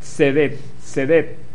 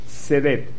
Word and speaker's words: tzedek: tzedek, con T-S-E, acento en la E, D-E-K, tzedek, T-S-E, con acento.